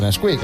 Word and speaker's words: NSQK: Bueno, se escribe NSQK, pero se dice Nesquik.